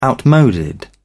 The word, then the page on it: outmoded: British English